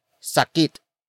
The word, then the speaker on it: sakit: Sumakit is spelled as S-U-M-A-K-I-T. It means became painful. The pain already happened. Sumakit comes from the root word sakit. Sakit. Sakit, which is spelled as S-A-K-I-T.